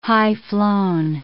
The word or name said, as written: high-flown